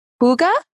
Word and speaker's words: hygge: But Kathie, I'm going to probably say it wrong again, even though she told me how to say it correctly. But the practice of hygge. – That's it. Woo!